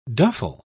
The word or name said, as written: duffel